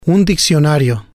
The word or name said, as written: un diccionario